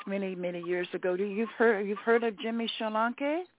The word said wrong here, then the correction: Sholanke: Yes, Sholanke. We call it Sholanke. That is the correct pronunciation. Sholanke.